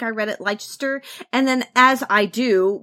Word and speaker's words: Leicester: It's spelled L-E-I-C-E-S-T-E-R. So that could be pronounced a couple of different ways. – I pronounced it Leicester because I'm pretty sure that's the same way as it's spelled in like Leicester Square in London.